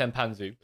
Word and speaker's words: Mpanzu: I think you pronounce it as, which is Mpanzu. It's Mpanzu.